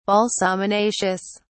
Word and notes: balsaminaceous: British English

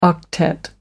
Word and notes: octet: American English

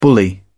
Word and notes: bully: British English

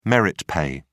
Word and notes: merit pay: British English

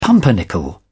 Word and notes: pumpernickel: British English

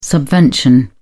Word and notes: subvention: British English

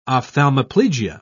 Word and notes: ophthalmoplegia: British English

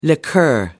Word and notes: liqueur: American English

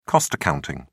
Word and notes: cost accounting: British English

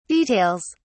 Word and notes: details: British English

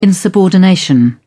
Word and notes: insubordination: British English

